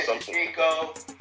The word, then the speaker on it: Shevchenko: Valentina Shevchenko. I can't even pronounce it, bro. – Yeah, Shevchenko.